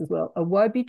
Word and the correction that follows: Ojibwe: tribe, Ojibwe. No, how do you say that? – The Ojibwe. – The Ojibwe tribe.